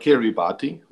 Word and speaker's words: Kiribati: But are we talking about Kiribati? – So I will explain. Every word ending in ti is it's pronounced s. So the thing is, Kiribati, as you write it, it consists of like huge atoll groups.